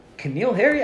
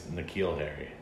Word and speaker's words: N'Keal Harry: Keneal Harry? I thought that started with a K. – I think it's N'Keal Harry. – N'Keal Harry. Yeah. I've been saying it wrong this whole time.